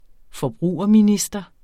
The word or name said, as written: forbrugerminister